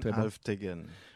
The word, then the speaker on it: Alvtegen: Karine Alvtegen.